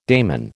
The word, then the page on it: daimon: American English